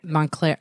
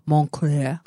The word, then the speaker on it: Montclair: Montclair. I say it like it's French, Montclair.